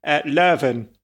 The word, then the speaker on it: Leuven: Yes, it's very confusing because it depends on what language you're speaking. Leuven is... – Leuven.